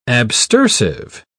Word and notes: abstersive: British English

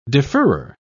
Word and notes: deferrer: British English

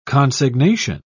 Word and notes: consignation: British English

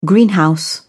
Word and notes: greenhouse: British English

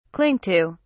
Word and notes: cling to: British English

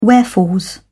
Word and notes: wherefores: British English

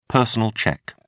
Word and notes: personal cheque: British English

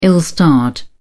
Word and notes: ill-starred: British English

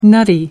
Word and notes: nutty: American English

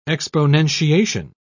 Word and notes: exponentiation: British English

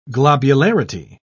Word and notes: globularity: British English

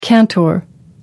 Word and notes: cantor: American English

